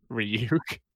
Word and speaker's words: Ryuk: Ryuk and Watari.